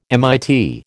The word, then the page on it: MIT: American English